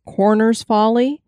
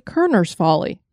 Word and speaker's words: Kerner's Folly: Kelly, on this episode, we are bringing people a location that looks like it's pronounced Corner's Folly, but it's actually Kerner's Folly.